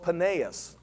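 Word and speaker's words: Paneas: It was also called Peneus, or how you pronounce it sometimes over there would be Banaeus.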